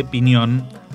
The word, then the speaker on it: Piñón: Pinón es un arcaicismo de Piñón anterior a la gramática de Nebrija. Se escribe con todas las letras unidas, Pinón, y su pronunciación correcta era y debería ser Piñón.